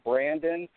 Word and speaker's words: Brandon: My personal page is Brandon, B-R-A-N-D-O-N, last name Kuchera. It's spelled K-U-T-C-H-E-R-A.